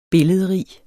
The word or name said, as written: billedrig